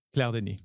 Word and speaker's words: Claire Denis: How do you say it in French? – Claire Denis. Yeah, you said it really well.